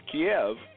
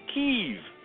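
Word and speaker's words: Kyiv: It's not pronounced Kiev, it's pronounced Kiev.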